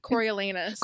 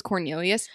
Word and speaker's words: Coriolanus: Wait, how do you say his name? – Coriolanus. – Coriolanus. Okay, because I was like, every time I read it was Cornelius to me.